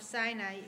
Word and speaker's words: Sinai: Yeah, I think the Hebrew pronunciation of Sinai is almost identical to the Hebrew word for hatred, Sinah.